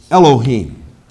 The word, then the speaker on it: Elohim: Elohim. E-L-O-H-I-M. Pronounce this like H-E-E-M. Elohim. The word El.